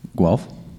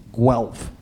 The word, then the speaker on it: Guelph: How do you pronounce that? – Guelph. – Guelph. I never would have guessed there's a W sound in there.